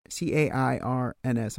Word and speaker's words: cairns: These stacking rocks, so these are known as cairns, and they originate cairns, C A R C A I R N S. I think I'm pronouncing that correctly. – Cairns, not cairns.